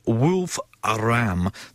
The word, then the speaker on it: Wolfram: And it's called, it's not easy to pronounce, Wolf Aram. That's wolf as in dog wolf. Wolf Aram as in sheep and ram.